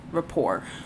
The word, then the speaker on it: rapport: Okay, R-E-P-O-I-R-E. Rapport. – R-E-P-O-I-R-E? – Yeah. – No. It is R-A-P-P-O-R-T, so it does have a silent letter, I knew it had a random fucking throw in there.